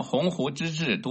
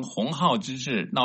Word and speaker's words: 鸿鹄之志: 十月末，北京大学领导重新洗牌，在北大 120 年校庆致辞时，将鸿鹄之志读成鸿号之志，闹出笑话。